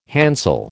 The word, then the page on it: handsel: American English